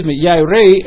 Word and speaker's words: yare: This particular word is yeri in the Hebrew, Y-A-R-E, long A, long E.